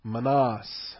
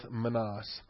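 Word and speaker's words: minas: All right, so this is episode number 40, the parable of the manas. That's how I've decided I'm going to pronounce that. It has a variety of different pronunciations in English, but we'll go with manas.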